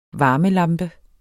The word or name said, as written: varmelampe